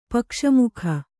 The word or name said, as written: ಪಕ್ಷಮುಖ